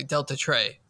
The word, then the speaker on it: Deltatre: That I believe I'm pronouncing it correctly, Delta Trey, right, Ian? – Yes.